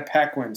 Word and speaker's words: Paquin: It also stars the late Sean Connery, Anna Paquin. Am I saying that right? – Anna Paquin, excuse me. – I believe that's how you say it.